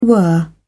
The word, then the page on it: were: British English